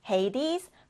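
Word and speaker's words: Hades: 영어 발음으로는 헤이디스, 포사이돈, 제우스.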